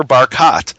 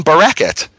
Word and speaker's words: Barkat: And it turns out Jerusalem's mayor is called, his name is Ner Barkat, Ner Carbuncle. and half the Jerusalemites call him Bereket, and they call him Bereket because that's the way the word carbuncle is pronounced in Exodus, and far more people are familiar with Exodus than they are with Ezekiel.